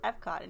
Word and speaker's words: Epcot: Guardians of the Galaxy really does belong in Epcot from the jokes that they tell. Gamora goes and tells you, like, we're going back to Epco at one point. And then Rocket responds with, it's pronounced Epcot, which is really funny.